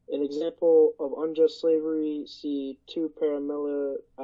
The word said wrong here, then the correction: Paralipomenon: Yeah, paralipomenon, it's horrible.